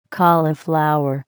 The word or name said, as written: cauliflower